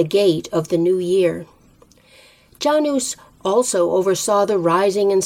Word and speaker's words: Janus: The month of January was named for the Roman god Janus, or Janus to give it its Latin pronunciation.